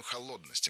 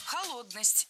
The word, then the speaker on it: холодность: Холодность. Неверно. Правильно, холодность. Ударение в этом слове такое же, что и в исходном существительном. Холод, холодность. Легко запомнить.